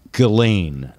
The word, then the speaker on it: Ghislaine: I know, but it's Ghislaine. It's like Elaine with a G on the beginning of it.